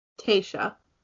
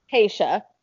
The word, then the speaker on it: Tayshia: Tayshia. – Tayshia.